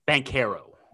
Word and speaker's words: Banchero: How are we pronouncing it again? – Banchero. – Banchero. Yeah.